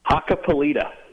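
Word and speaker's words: Hakkapeliitta: How do you pronounce that? – Hakka Polita.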